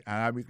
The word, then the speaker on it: Arabic: Prononciation correcte là, c'est Arabic.